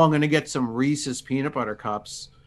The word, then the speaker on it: Reese's: I do know it's pronounced Reese's, and I do know that I was pronouncing it wrong.